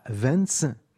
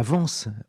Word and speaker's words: Vence: Lawrence est décédé en 1930 à Vence, non, mauvaise prononciation, à Vence, autre ville d'artistes dans les Alpes-Maritimes.